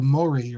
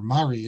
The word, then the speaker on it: Mori: It's called Mori, or Mari is how they pronounce it in Yemen.